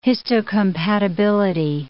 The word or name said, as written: histocompatibility